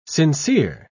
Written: sincere

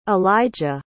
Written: eligah

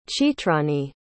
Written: chitrani

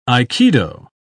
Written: aikido

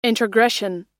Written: introgression

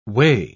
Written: weigh

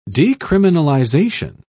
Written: decriminalization

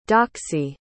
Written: doxie

